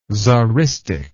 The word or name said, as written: tzaristic